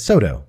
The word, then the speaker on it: SoDo: Yeah, you're putting the emphasis in the wrong place. – What is it? – It's SoDo, like soda, but with an O.